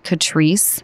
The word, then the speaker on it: Catrice: First of all, I believe you pronounce it Catrice, okay. C-A-T-R-I-C-E.